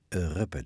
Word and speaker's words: Ruppe: Karel Friedrich Ruppe, daar heb ik het over. Hij was al snel na zijn komst naar Nederland helemaal Nederlander. En vandaar dat we zijn naam ook op zijn Nederlands uitspreken.